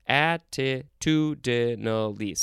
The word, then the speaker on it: attitudinally: How many syllables does that have? Attitudinally. Six syllables. That's a lot. The etymology says this is from attitude plus the suffix I-N-A-L, inal, as in aptitudinal.